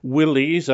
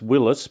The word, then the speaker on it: Willys: Did he think the Willys, I know some say it should be pronounced Willis, but I think we all know it as Willys. – Willys, yeah.